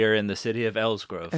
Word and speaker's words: Elsgrove: So Elsgrove. It is spelled E-L-S-G-R-O-V-E. And that... Like it's in the name, a grove.